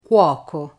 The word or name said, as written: cuoco